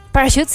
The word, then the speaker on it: Parachutes: Parachutes, sí se pronuncia bien.